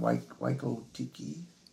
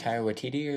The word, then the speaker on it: Taika Waititi: So I'm like, and then I always butcher his name, Waiko Tiki. – Is that a... – That's the that director of Thor Ragnarok and... – Oh, I can't ever say. – Taika Waititi or something? – Yeah, like, he's in it.